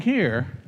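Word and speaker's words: he (ה): By the way, to pronounce the word, the letter he, see how you have to breathe out?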